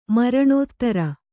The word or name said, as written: ಮರಣೋತ್ತರ